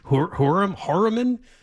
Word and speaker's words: Heuermann: A guy on Long Island, New York, his name is Rex, I think it's pronounced Hurman, H-E-U-E-R-M-A-N-N, 59-year-old married architect.